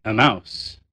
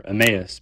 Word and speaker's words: Emmaus: It's kind of like that town in the resurrection narrative, which some people say Emmaus and others say Emmaus. I prefer the former, Emmaus, because the other pronunciation sounds like you're saying a mouse, like the rodent.